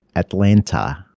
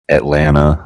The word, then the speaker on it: Atlanta: The second T in Atlanta is silent. – Oh, okay. Atlanta. – Atlanta, Havana.